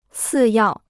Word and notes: 次要: cì yào

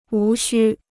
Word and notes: 无须: wú xū

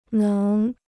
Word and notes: 能: néng

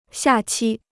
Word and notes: 下期: xià qī

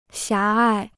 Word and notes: 狭隘: xiá ài

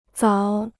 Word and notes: 枣: zǎo

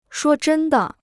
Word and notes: 说真的: shuō zhēn de

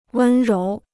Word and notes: 温柔: wēn róu

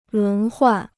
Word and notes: 轮换: lún huàn

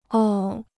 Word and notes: 哦: é

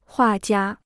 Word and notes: 画家: huà jiā

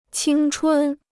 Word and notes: 青春: qīng chūn

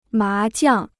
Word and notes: 麻将: má jiàng

